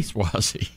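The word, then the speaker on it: Suozzi: Swizzy? – Swazi.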